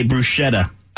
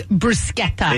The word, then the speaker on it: bruschetta: Well, we say bruschetta. – Okay. It's bruschetta. – It is bruschetta.